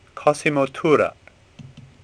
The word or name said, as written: Tura